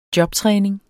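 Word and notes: jobtræning: Danish

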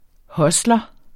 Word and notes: hustler: ˈhʌslʌ

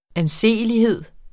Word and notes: anseelighed: anˈseˀəliˌheðˀ

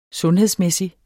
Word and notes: sundhedsmæssig: -ˌmεˀsi; -ˌmεsi

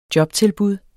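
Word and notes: JOBTILBUD: Danish

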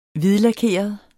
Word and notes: hvidlakeret: ˈviðlaˌkeˀʌð; ˈviðlɑˌkeˀʌð

